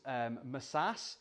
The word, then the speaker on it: masas: M-A-C-A-C, it's actually pronounced like the one in the brackets, masas.